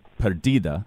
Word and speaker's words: Perdida: So the album's called Perdida. I'm saying that right, right? – Yeah, okay. Absolutely.